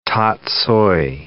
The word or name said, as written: tatsoi